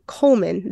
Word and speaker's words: Coleman: Coleman or Coldman? Coleman. The D is silent.